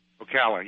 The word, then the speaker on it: Ocala: Did I say that right? – Ocala, yes.